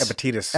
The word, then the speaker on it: Epictetus: And this individual, I always mess up the pronunciation of the name, but Epictetus? – Epictetus. – Epictetus.